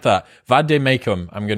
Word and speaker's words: vade mecum: What is a vade mecum? – A vade mecum is a Latin.